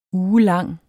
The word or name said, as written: ugelang